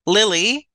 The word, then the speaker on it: Lily: Lily is spelled and pronounced like the flower.